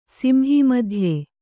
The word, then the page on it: ಸಿಂಹೀಮಧ್ಯೆ: kannada